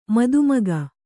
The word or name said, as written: ಮದಮುಖ